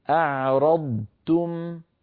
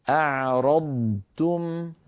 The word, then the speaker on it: أعرضتم: إذا جاءت ضاد بعدها تاء فالحكم الإظهار، فنبين صوت الضاد ثم بعد ذلك ننتقل إلى حرف التاء هكذا. أعرضتم، أعيدها لك، أعرضتم، أعرضتم، ردتم، ضاد ساكنة بدون أصوات زائدة ثم تاء.